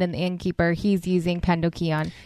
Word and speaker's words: pandocheion: He's using pandocheion. Is that how you'd probably pronounce it? – Pandocheion. – That's probably more Latin, huh? – Pandocheion. – Pandocheion.